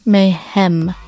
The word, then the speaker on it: mayhem: Wait, is it Mayhem or Mayhem? – Mayhem. Like, are you trying to spell it? – Or? Yeah, I'm trying to spell it in my head. – Hem, like E. – So, M-A-Y-H-E-M.